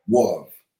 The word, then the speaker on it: Gouyave: Guav is Guav, right? – Guav. – Guav, right? – Guav is spelled G-O-U-Y-A-V-E.